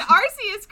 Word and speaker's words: Arceus: You pronounced Arceus correctly.